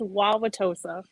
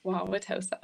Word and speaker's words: Wauwatosa: Yeah, it's Wauwatosa. – Wauwatosa. – And we keep saying Tosa. – Okay. I was like, I'm looking at my notes here and I'm like, what is happening? Do you not pronounce anything before the T? – It's all silent.